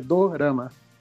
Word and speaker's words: dorama: Que é drama, né? Só que é dorama.